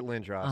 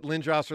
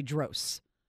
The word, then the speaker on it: Lindros: Yeah, for years, I phonetically spelled it in my updates so that I wouldn't say Dros. – You were accustomed to thinking Dros.